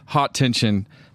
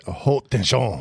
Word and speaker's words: haute tension: pronounce it haute tension. – Haute tension. Haute tension. Right? Uh, it's not high tension. It's called high tension, but in French it's pronounced what? Haute.